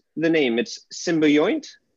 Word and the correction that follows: Symbiont: Symbiont. You can say symbiont or symbiont. Those are both acceptable pronunciations. But think about symbiosis.